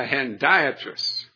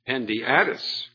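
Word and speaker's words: hendiadys: So he said this was a hendiatrus. Hendiatrus. I used to pronounce it Hendiatus, until he said it another way.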